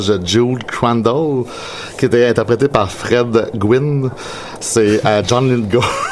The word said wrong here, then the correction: Gwynne: C'est pas Fred Gwynn. – Mais comment tu le prononces? – J'ai essayé de pas rire, là. Mais... – Non, ben, je t'entends. – Comment tu le prononces? – Ben, Fred Essaye, vas-y. – Fred Gwynne. On va dire Gwynne.